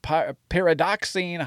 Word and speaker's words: pyridoxine: I can't pronounce most of the pyroxidine hydrochloride. That's pyridoxine hydrochloride.